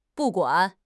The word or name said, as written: bu4 guan3